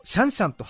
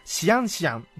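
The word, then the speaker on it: 香香: ちなみにシャンシャンをシャンシャンと発音すると中国語の先生には怒られますよ。 – 正しくは、シアンシアンです。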